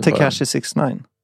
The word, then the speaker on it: Tekashi 6ix9ine: Bilden, ja, Tekashi 6ix9ine. Men som Fredrik kallar 69 inches. – Hur uttalar man det? – Tekashi 6ix9ine.